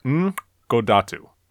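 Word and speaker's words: N!godatu: For the first season, at least, the Simpsons took turns with another series of animated shorts called, and I'm going to really try to pronounce this correctly, Dr. Ngodatu, spelled N exclamation point G-O-D-A-T-U. There's a clicking syllable in there represented by an exclamation point that's commonly found in African languages.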